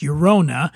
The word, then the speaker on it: Llorona: And that's Llorona. Pronounced Llorona. Tears of Llorona is a very exclusive extra añejo tequila created by master distiller Herman Gonzalez.